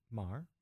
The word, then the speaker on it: Maher: I always say his name wrong. – I think it's Maher. – Maher, is it right? – I think okay.